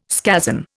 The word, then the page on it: scazon: Indian